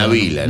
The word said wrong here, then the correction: Nadila: Nadila, no Navila,